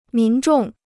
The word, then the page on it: 民众: mín zhòng